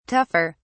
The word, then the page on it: tougher: British English